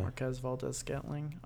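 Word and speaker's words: Marquez Valdes-Scantling: Marquez Valdez-Scantling. Is that how you say it? – Marquez, yeah. – Marquez Valdez-Scantling.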